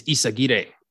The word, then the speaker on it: Isagire: I found out a few years ago, I've been mispronouncing my last name my whole life. I've been saying it the white way, like, the correct pronunciation is Isagire, but I've been saying it the white way for so long that that doesn't sound right to me.